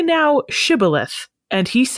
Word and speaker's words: Shibboleth: They pronounced the SH sound as just an S sound. – Shibboleth.